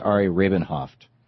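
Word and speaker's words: Ari Rabin-Havt: Shano tells me it's pronounced Ari Rabin-Hoft.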